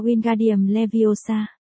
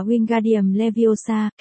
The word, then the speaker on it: Wingardium Leviosa: Nó vung vẩy cánh tay như cánh quạt gió, đọc to Wingardium Leviosa. Mọi người đều nghe Sabrina cau nhau. Bạn đọc sai rồi, phải đọc là Wingardium Leviosa, kéo dài chữ ga một cách duyên dáng.